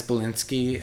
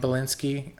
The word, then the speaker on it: Belinsky: You know, our last name is Belinsky, and there's all this speculation that a number of things could have happened that gave us the name Belinsky.